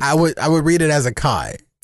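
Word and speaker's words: açaí: So how would you pronounce A-C-A-I? – Well, I know how people pronounce it, but it's really pronounced acai.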